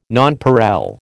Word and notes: nonpareil: American English

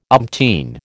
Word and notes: umpteen: American English

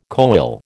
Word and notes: koel: American English

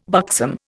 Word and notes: buxom: Indian

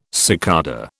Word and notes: cicada: British English

